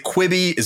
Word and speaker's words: Quibi: Is it Quibi? Is it Quibi? I don't know what they call it. – You had it right the first time.